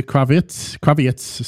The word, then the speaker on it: Kravitz: Yeah, it's Peter Kravitz. Sorry, I always want to pronounce it like Lenny, but it's not.